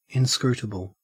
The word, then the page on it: inscrutable: Australian English